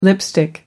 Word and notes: lipstick: American English

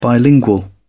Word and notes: bilingual: British English

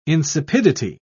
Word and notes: insipidity: British English

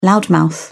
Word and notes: loudmouth: British English

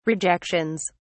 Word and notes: rejections: British English

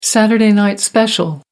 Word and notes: saturday night special: American English

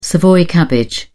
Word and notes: savoy cabbage: British English

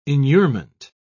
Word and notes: inurement: British English

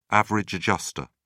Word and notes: average adjuster: British English

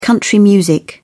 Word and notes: country music: British English

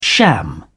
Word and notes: sham: British English

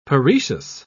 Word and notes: paroecious: British English

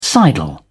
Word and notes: sidle: British English

